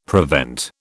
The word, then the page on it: prevent: British English